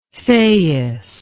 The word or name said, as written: Thais